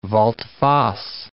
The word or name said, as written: volte-face